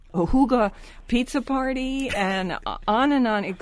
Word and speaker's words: hygge: And don't forget, people, it's spelled, The Hygge Life is the name of the book, and it's spelled H-Y-G-G-E, but you pronounce it in the language Hygge. You know, H-O-O-G-U-H, Hygge, like that.